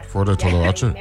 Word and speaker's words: Flor de Toloache: What we'll do is we'll take a break now and we'll get back and talk a little bit more with Flor de Toloache. That's how you pronounce it? – Yeah. – Flor de Toloache. – Nailed it.